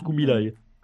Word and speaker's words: Kubilai: Je pense que ça se prononce Kubilai, comme Kubilai Khan, mais...